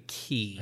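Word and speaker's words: qi: Qui is a good one. – Yes. Yeah. Is that how it's pronounced? – Qui? – Q-I? – Q-I? – Pretty sure that'd be key.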